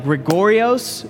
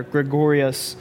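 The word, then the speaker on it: Gregorios (γρηγορέω): It's like Gregorios. It's spelled, it was funny, this is how I remembered it. It is spelled Greg and Oreos. Put those two words together, Gregorios.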